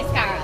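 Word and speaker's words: Isarka: I can't pronounce her name, but it's I-S-A-R-K-A. – Iskara. – Yes.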